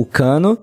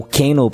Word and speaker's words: Kano: Tinha o Richard Divisio também, que fez o, o Cano, né? O Kano, para quem preferia a pronúncia mais certinha.